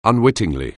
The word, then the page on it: unwittingly: anglais